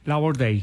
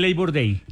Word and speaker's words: Labor Day: Gabrielito, imagínate que un día como hoy en Estados Unidos se celebra el Labor Day o Día del Trabajo en Estados Unidos. – ¿El qué? – Labor Day.